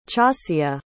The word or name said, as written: chasya